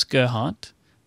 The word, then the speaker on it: Gerhardt: I know how an American would and a German would pronounce my last name. It's Gerhardt. – The D is like, you know, silent.